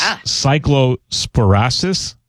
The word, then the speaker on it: cyclosporiasis: The illness, and you pronounced it very well before, it's cyclosporiasis. Is that it? – How did you say it? – Sporiasis. – Think. Okay. – Yeah, but very well done.